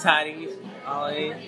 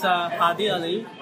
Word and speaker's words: Hadi Ali: My name is Hadi Ali. That's how you pronounce it, American-wise. It's Hadi Ali, if you say it like Middle Eastern way.